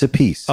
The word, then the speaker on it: Appice: or is it a Peachy? How do we say it? – Well, it's funny. If I'm not mistaken, it's a piece.